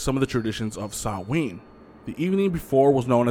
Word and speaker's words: Samhain: It originated with the ancient Celtic festival of Samhain, but it's pronounced Samhain, and people would light bonfires and wear costumes to ward off ghosts.